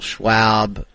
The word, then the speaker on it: Schwab: I want to thank the Charles Schwab Invitational. – Schwab. It's like swag. Swag.